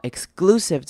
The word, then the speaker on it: exclusive: 好，exclusive 这个字要特别注意的，它的 c 的念法呢，我们并不会念成 c 的声音出来，不会念成 exclusive，会念成一个 g 的声音，也是一样念成个个个，念成 exclusive，念成 glusive，exclusive onset photos。